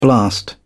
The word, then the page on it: blast: British English